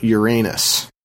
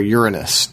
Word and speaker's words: Uranus: In Greek mythology, Enceladus is one of the giants, Gaia's children, fertilized by the blood of castrated Uranus. Or Uranus, depending on how you like to pronounce that.